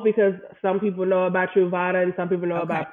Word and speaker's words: Truvada: But you talked about a medication called PrEP or Ultra, or let me make sure I'm saying it right, Ultra Bravada, right? – It's PrEP or Truvada.